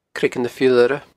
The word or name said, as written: Creagan na h-Iolaire